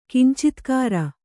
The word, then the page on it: ಕಿಂಚಿತ್ಕಾರ: Kannada